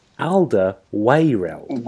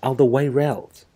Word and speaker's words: Alderweireld: It's not, I keep saying Alderweireld. Alderweireld, obviously, the Spurs Belgium centre-back. Apparently, it's Alderweireld. I didn't know. Alderweireld, guys.